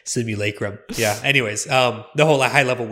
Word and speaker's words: simulacrum: It's actually, just to confuse even more, I did a little pronunciation thing here. It's simulacrum, not lacrum.